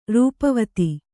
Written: ರೂಪವತಿ

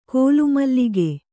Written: ಕೋಲುಮುಲುಕ

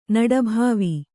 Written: ನಡಬಾವಿ

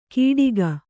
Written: ಕೀಡಿಗ

